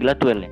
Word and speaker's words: Gladwell: Gladwell, bacanya Gladwell ya. – Well, iya.